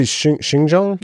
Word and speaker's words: Xinjiang: Because clearly, like, Xinjiang proves that things are very flexible when money's involved. – You're talking about Xi? – What? Yeah, well, I'm saying, like... – What did you say, Xinjiang? – Yeah, that's how you pronounce it.